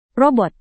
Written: robot

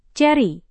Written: ceri

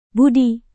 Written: budi

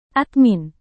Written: admin

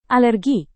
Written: alergi

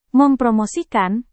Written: mempromosikan

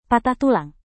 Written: patah tulang

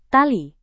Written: tali

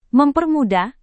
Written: mempermudah

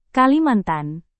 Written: kalimantan

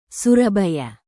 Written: surabaya

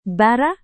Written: bara

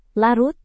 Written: larut

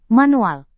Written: manual

